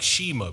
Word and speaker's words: Shema: It's spelt like Shema, but they say it Shema.